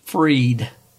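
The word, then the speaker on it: Fried: A pitcher named, pronounced Freed, shuts out the opposition like Tom Terrific shut out the Indians in 1995.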